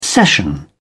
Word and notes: session: British English

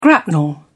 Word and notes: grapnel: British English

